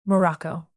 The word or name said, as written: morocco